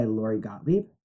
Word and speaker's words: Lori Gottlieb: It's a quote by Lori Gottlieb. Did I say that correctly? – Yes, you did.